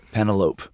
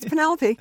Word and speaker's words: Penelope: And first of all, when I first started following your movies, I thought you pronounced your name Penelope. – Oh, yeah, it sort of rhymes with cantaloupe. No, it's Penelope.